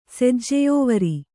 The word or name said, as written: ಸೆಜ್ಜೆಯೋವರಿ